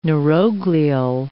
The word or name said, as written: neuroglial